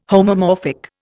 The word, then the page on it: homomorphic: Indian